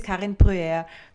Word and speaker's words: Karen Brewer: I hope I'm pronouncing that right. I should have checked that beforehand. I'm so sorry, Karen. – No worries. Hello, welcome. My name is Karen Brewer, but that's French. – Much, much better pronounced by yourself. It's absolutely amazing to have you on the podcast today.